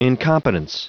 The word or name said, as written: incompetence